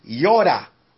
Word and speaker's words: yada: It says, the Hebrew word is yoda, and it's spelled Y-A-D-A.